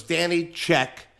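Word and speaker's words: Danny Czek: Danny, D-A-N-N-Y, C like in Charlie, Z like in Zebra, E-K. So it's Danny Check, but it's spelled the Polack way because I'm a Polack.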